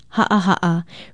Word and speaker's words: ha'aha'a: Ha'aha'a is spelled with two okina or glottal stops, H-A-Okina-A, and pronounced ha'aha'a.